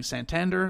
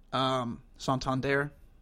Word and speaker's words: Santander: Shane Payne corrected me on stream on Friday. I had been saying Santander, like a white person, but apparently that's not correct. Santander, I think.